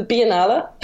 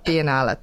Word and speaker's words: Biennale: and has represented Ireland at the Venice Biennale. I can never pronounce it. Take it away. How do you pronounce it? – The Biennale. – Biennale. Thank you.